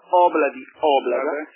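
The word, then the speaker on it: Ob-La-Di, Ob-La-Da: che si chiama Obla di Oblada, con l'accento sulla prima vocale.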